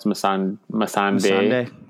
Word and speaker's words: Missandei: You know, we had a big death, the beheading by the Mountain of Missandei. I'm sure I'm pronouncing that incorrectly. – I think there's no R. It's Missandei.